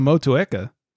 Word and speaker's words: Motueka: But good job on Motueka. – Thank you. – That was good pronunciation.